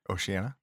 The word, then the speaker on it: Oceania: Oceania. – Oceania.